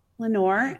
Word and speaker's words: Leonor: Yes, so corporate candy wants to buy Deseo, but Lenora, what'd you say her name was? – Lenore, L-E-O-N-O-R. Okay. Lenore